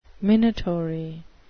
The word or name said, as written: minatory